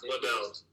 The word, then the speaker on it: Liddell: Liddell. – Liddell? – Yeah, AJ Liddell.